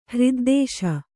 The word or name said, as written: ಹ್ರಿದ್ದೇಶ